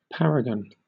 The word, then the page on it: paragon: British English